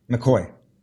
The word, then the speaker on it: McGahey: Is it pronounced McGahey? – McCoy. Unlikely, but all those letters turn into McCoy.